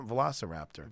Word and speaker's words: Velociraptor: Yeah, Velociraptor.